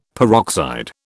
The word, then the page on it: peroxide: British English